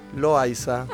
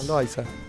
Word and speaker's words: Loaiza: Loaiza. – Ah, sí, como Loaiza, como... digo yo. – Claro. Igual también es válido. Poner a la H algún sonido, pero no, Loaiza.